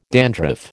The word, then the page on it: dandruff: American English